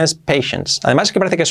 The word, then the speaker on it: patience: Pero paciencia, como tal, la paciencia, se escribe patience, pero la pronunciación es patience. Parece que es una T, pero no, patience. Es la N y la S ahí, patience, ¿vale? Patience, patience, acabado en S.